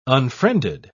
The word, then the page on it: unfriended: British English